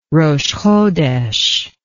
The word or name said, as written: rosh hodesh